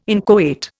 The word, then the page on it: inchoate: Indian